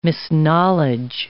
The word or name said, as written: misknowledge